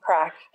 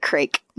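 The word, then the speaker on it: craic: No, it's spelled the Gaelic. It's C-R-A-I-C. – Oh, okay. Yeah. I would have pronounced it crake.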